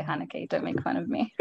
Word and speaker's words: Haneke: Oh, actually, which pronunciation of Haneke are we going with? Because I was just watching an interview with him, and it's like Haneke, and I was like, I don't think how you say it. – Anglo Haneke, or we can go the sophisticated Euro Haneke.